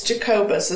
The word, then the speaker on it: Jacobus: The artist was Tim Jacobus. – And is it Jacobus or Jacobus? – It's Jacobus is how... – That's what we're going with.